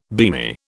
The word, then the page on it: beamy: British English